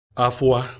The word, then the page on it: afua: Efik